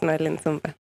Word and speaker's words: Noelyne Sumba: Yes, Noelyne Sumba.